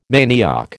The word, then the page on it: manioc: American English